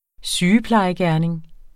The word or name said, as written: sygeplejegerning